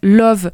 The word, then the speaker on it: Lauv: Il s'appelle Love. Ça s'écrit L-A-U-V.